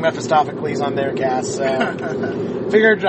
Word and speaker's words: Mephistopheles: Did you say Mephistopheles? – Mephistopheles. – Oh. Yeah, you had to see. – Because it's Greek. He's a Greek.